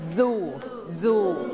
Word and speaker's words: zoo: garden นะคะเก่งมากออกเสียงเลย zoo zoo เราไม่ออกเสียงว่าซูนะ zoo zoo